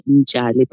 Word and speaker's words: Mtshali: My surname is actually spelled out as M-T-S-H-A-L-I, which is basically pronounced as Njali.